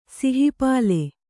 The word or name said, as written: ಸಿಹಿಪಾಲೆ